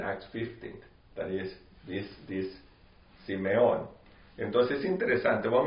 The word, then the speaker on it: Simeon: So, Simeon, how would you say that in English? – Simeon.